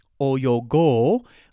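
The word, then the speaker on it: 泳ごう: so oyogu is to swim, oyogo, a long O there, is going to be to, tr- to let's swim, right?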